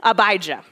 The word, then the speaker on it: Abijah: Abijah is how you say it, Abijah.